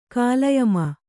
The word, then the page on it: ಕಾಳಯಮ: kāḷayama